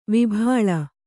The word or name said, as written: ವಿಭಾಳ